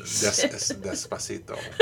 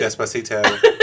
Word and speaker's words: Despacito: I'm glad you're teaching us the right pronunciation. – Despacito. – I could literally say it the exact same way you have, and it won't nearly sound as good.